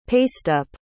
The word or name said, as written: pasteup